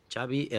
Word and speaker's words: Xavi: Xavi Hernández. – Xavi. – Yeah. – Okay. So I heard the Xavi like chocolate.